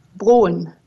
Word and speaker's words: Broen: Broen.